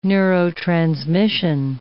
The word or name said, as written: neurotransmission